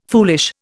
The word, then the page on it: foolish: Indian